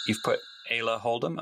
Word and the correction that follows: Isla Holdham: am I saying that correctly? – And Isla. – Isla. – Yeah, spelled Isla Holdham. – Isla Holdham. Yeah,